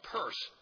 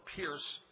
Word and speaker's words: Peirce: They were led by a man named Charles Peirce, who you probably haven't heard of, or Pierce, some pronounced it.